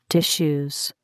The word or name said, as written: tissues